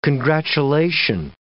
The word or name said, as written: congratulation